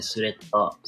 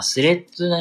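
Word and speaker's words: Threads: スレッドですね、スレッダー。スレッダーやって、スレッダーでよかったよね。未だにごめんなさい、読み方がわからんけど、あ、スレッドね、スレッドね、そうそうそう、スレッド。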